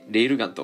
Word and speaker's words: 超電磁砲: えー、これ、超電磁法と書いて、レールガンと読みます。はい。えー、このアニメの名言について話します。あの、まず、このレールガン